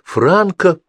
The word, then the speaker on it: франко: «Не забудьте, что 2,40 франка привоз». С ударением повторил «Франко привоз». – «А, да, Франко», — пробормотал Василий Петрович.